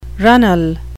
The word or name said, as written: runnel